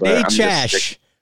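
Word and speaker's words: Necas: Did you hear the new pronunciation for Martin Natchez tonight? – Yeah, I know. Don Waddell pushes that one pretty hard. – Natchez. We got a Natchez out of NBC Sportsnet tonight.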